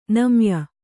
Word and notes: ನೈಮೇಯ: naimēya

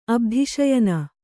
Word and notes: ಅಬ್ಧಿಶಯನ: kannada

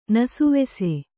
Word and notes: ನಸುವೆಸೆ: kannada; nasuvese

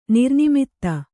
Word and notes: ನಿರ್ನಿಮಿತ್ತ: nirnimitta

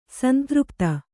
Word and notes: ಸಂತೃಪ್ತೆ: santřpte

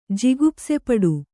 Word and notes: ಜಿಗುಪ್ಸೆಪಡು: Kannada

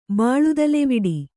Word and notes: ಬಾಳುದಲೆವಿಡಿ: kannada

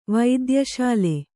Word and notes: ವೈದ್ಯಶಾಲೆ: vaidya śale

